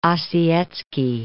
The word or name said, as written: Ossietzky